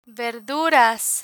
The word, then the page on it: verduras: Spanish